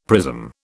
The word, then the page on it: prism: British English